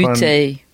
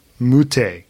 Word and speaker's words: Moutet: Corentin Moutet. – Moutet, yeah. against Dan Evans. Oh, my word. I've never seen Mute play a full match.